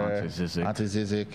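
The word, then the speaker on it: Ante Žižić: Ante Zizek. – Is that how you say his name? – Yeah.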